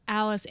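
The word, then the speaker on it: Alys: So I very much believe that it's pronounced Alice.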